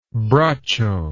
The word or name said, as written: braccio